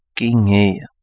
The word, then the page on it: gingeia: kapingamarangi